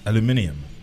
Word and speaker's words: aluminium: I believe it's pronounced aluminium.